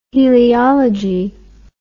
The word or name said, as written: heliology